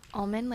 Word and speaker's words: Almond: Now anybody asks me to spell it, I'm like, Almond, like the nut.